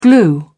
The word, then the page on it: glue: British English